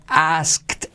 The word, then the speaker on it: asked: My friend brushed, con SH pero pasado, brushed me off when I asked, la K lo omitimos, asked, when I asked for help.